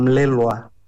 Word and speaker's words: Melelgua: Ayúdeme con su apellido, ¿cómo tengo que pronunciarlo bien? – Ya has hecho muy bien, Melelgua.